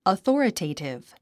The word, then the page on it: authoritative: əθɔ́ːrətèitiv; アソォラテイティブ